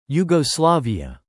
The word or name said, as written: Yugoslavia